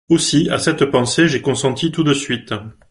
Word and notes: consenti: French

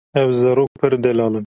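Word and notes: delal: Kurdish

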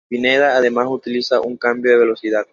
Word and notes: cambio: ispanyolca

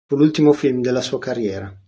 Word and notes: sua: italian

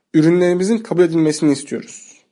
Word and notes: kabul: turc

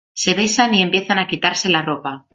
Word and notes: quitarse: spanish

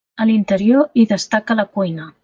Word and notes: destaca: català

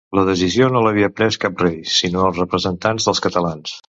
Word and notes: representants: Catalan

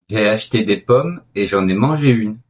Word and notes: pommes: French

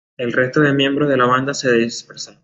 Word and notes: dispersaron: ispanyolca

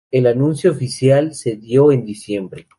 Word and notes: anuncio: spanisch

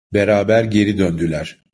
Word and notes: geri: turco

